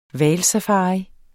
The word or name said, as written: hvalsafari